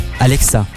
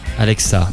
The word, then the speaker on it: Alexa: C'est Alexia. Alexa. – Alexa.